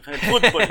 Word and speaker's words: fútbol: Llamamos fútbol con acento en la U. El, fútbol.